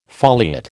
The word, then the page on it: foliate: American English